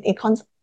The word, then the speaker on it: ECOSOC: And ECOSOC, is it? – ECOSOC, Economic, Social and Development Unit.